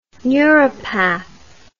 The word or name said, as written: neuropath